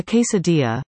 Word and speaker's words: quesadilla: A quesadilla, pronounced quesadilla, is a filled and folded tortilla that's cooked until golden on the outside and the filling is hot.